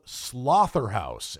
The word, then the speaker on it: Slotherhouse: And that movie is called Slotherhouse. And no, I did not mispronounce that. S L O T H E R H O U S E.